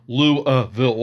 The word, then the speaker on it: Louisville: Don't do Louisville or Louisville. Louisville. Well, that's how how they pronounce it.